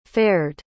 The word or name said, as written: fared